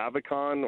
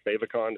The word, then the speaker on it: favicon: So there's something called a favicon or favicon, depending how you pronounce it.